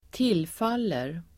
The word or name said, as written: tillfaller